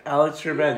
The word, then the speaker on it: Trebek: Alex Trebek, yeah.